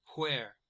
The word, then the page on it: where: American English